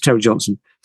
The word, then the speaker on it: Terry Johnson: Sorry if I said the name wrongly before. It's Terry Johnson.